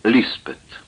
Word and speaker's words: Лиспет: Котгархский пастор назвал ее Элизабет, Лиспет, как произносят в горах на языке пахари.